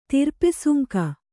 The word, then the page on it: ತಿರ್ಪೆಸುಂಕ: Kannada